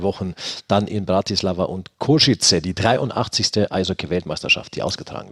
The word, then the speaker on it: Košice: Ganz Bratislava war eigentlich 2 Wochen Feierfest und jetzt kommt noch Košice mit hinzu. Richtig ausgesprochen, wie du es sagst, übrigens Košice habe ich gehört. – Košice. – Košice, dann werde ich das auch versuchen, so in Zukunft zu sagen.